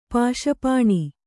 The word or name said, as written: ಪಾಶಪಾಣಿ